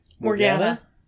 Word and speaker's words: Morgana: Morgana.